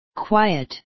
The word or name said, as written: quiet